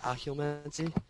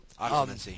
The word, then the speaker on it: Occlumency: Occlumency. Yeah. – Occlumency.